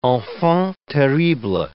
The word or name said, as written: enfant terrible